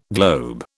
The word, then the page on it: globe: British English